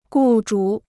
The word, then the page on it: 雇主: gù zhǔ